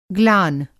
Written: ग्लान

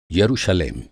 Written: यरूशलेम

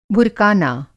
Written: भुरकाना

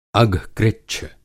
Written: अघकृच्छ

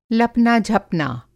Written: लपना-झपना

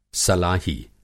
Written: सलाही

